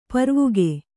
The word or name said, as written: ಪಾರ್ವಿಕೆ